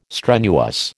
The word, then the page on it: strenuous: American English